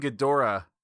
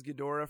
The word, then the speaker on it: Ghidorah: Well, I pronounced it as Ghidorah for a long time, and then it sounded dumb in my mouth, so I changed it to Ghidra because I thought I heard someone say it that way, but whatever.